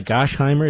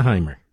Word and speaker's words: Gottheimer: Now, I have to admit, I don't agree with him on 90% of this stuff, but this guy, Gosheimer, isn't that how you pronounce it, Mr. Producer? Josh Gosheimer. Gottheimer.